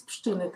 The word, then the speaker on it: Pszczyny: Aborcja znowu jest e, na językach debaty publicznej, głównie związanej z tragiczną śmiercią pani Izabeli z Pszczyna, dobrze mówię? Tak, z Pszczyny, tak.